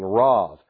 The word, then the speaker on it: Rav: The B can be pronounced with a V pronunciation, Rav.